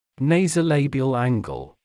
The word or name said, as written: nasolabial angle